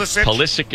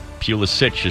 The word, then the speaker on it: Pulisic: Yeah, I think Pulisic is the American, Pulisic is the Croatian.